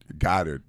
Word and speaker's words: Goddard: We knew ours was Dick Goddard. – Oh, Dick who? – Dick Goddard. – Dick Goddard. – Dick is his first name. Last name is Goddard. – Goddard, okay. – G-O-D-D-A-R-D.